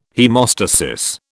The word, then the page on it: haemostasis: British English